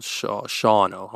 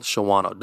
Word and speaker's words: Shawano: Almost called Shawano. Don't call it Shawano. They get upset. – It's spelled like that, though. – Yeah. Yeah, no. If it wanted Shawano, don't put the W in this.